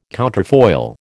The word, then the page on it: counterfoil: American English